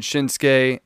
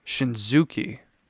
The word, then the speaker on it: Shinsuke: When I first found out who Shinsuke Nakamura was, the way that I pronounced his name, probably like a lot of Americans, was Shinzuki. Shinzuki Nakamura.